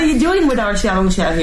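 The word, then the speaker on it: 小龙虾: Xiaolongxia.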